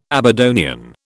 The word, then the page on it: Aberdonian: British English